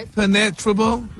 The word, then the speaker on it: impenetrable: Impenetrable. Impenetrable. – No, your emphasis is on the wrong syllable.